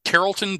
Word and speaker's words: Carrollton: He is a D-back from, let me know if I'm saying this right. Is it Carrollton, Georgia? Is that right? – Yes, sir. That's right.